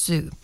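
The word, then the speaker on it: Zhu: It's Z-H-U. So I think it's Zoo.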